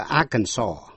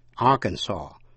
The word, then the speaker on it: Arkansas: Tên tiểu bang A-R-K-A-N-S-A-S, có âm S câm, đọc là Arkansas. Arkansas, chứ không đọc là Arkansas.